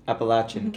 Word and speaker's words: Appalachian: How do you say it? – Appalachian. – Appalachian.